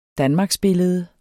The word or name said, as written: danmarksbillede